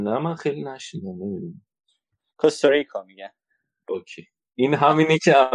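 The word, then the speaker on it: Costa Rica: کوستاریکا هست درسته من دیگه صحبتی ندارم راجع به کشور کوستاریکا که خیلی سواحل ثروتمندی دارم بله.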